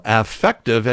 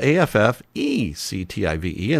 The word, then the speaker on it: affective: but person who is the supervisor of affective education. Now, that's not effective. It's spelled affective, and that means sort of the emotional side of education, affective education.